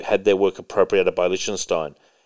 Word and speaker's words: Lichtenstein: The USPS recently released a commemorative series of stamps to honor infamous plagiarist Roy Lichtenstein.